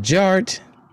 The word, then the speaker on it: DJarTT: DJ Art, with two T's for a double dose of that tink tink. The D is silent, so it's just Jart.